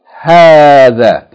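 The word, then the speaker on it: Hadha (هذا): In the same way that we said about hadha. The alif is not written, but it should be pronounced the first syllable long. Hadha.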